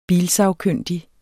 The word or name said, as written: bilsagkyndig